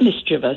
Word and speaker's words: mischievous: And tell me how you feel about the word "mischievous" being mispronounced. – I've heard it many, many times. It is mischievous.